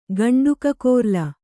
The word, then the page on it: ಗಂಡುಕಕೋರ್ಲ: gaṇḍu kakōrla